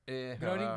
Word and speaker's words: Groening: Groening, bien pronunciado.